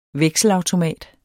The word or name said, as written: vekselautomat